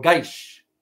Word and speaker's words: Kish: The second one is the Kish, or Gaish, as they pronounce it in Farsi.